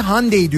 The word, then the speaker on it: Hyundai: Hyundai'ye çünkü Güney Kore'de Hyundai diyorlar. Orada öyle telaffuz ediliyor.